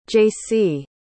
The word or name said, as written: jaycie